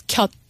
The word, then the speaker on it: 곁: You've got in the second line, 어제나 네 곁에, did I say that right? – Yes, 곁.